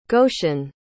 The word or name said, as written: goshen